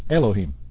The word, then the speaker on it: Elohim: The second name in our verse, Hashem Elohim, is spelled like the four-letter name but vowelized and pronounced Elohim, Yehovi.